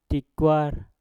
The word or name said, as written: tikwar